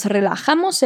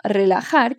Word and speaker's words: relajar: Lembra sempre que a pronúncia do R é bem vibrante e o J tem esse som de RR do português. Então, repete. Relajar. Relajamos.